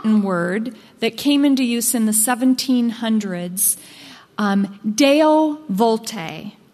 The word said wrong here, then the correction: Deo volente: It's spelled D-E-O, new word, V-O-L-E-N-T-E. Deo Volente, rather.